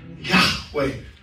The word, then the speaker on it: Yahweh: Yahweh is how it's pronounced because you can't say the vowels.